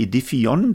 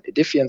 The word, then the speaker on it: aedifion: Beides perfekt. Man kann es Englisch aussprechen, man kann es Deutsch aussprechen. Es gibt ja auch das äh, Unternehmen Infineon oder Infineon. Genau, man kann Edifion sagen. Edifion kommt von Lateinisch Edificium, das Gebäude.